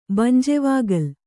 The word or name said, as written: ಬಂಜೆವಾಗಲ್